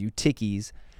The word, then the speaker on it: Eutyches: I say the American pronunciation, which is Eutyches.